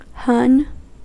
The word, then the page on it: hon: American English